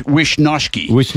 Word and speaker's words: Wishnowsky: Mitch Wishnowsky, it's pronounced, I'm led to believe.